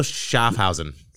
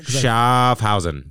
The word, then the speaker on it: Schaffhausen: We are butchering his name. – His German accent. – Say his name one more time. – Emil Schaffhausen. – Read it. I want you to read it to confirm it. – Schaffhausen. – Schaffhausen. – Yeah.